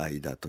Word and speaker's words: あひだ: あの海山間というアヒダと書いたアダと読